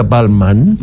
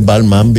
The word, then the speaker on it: Balmain: Así se escribe, se pronuncia Balmain. – Ya. – En francés, Balmain, ¿viste? – Sí, bueno, tú tienes una cosa ahí francesa.